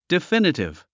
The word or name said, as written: definitive